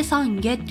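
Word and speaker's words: 散華: あの散るってね、花が散るの散るに華やかの方の花、これで三下って読むみたいなんですけど、そう調べたらね、素敵なデザインのものが出てきてね、あ、こういうものがもらえるのかなっていうイメージがついたんですけど、そうなんかね、仏教のなんかご縁の証的なやつらしいです。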